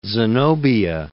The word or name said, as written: Zenobia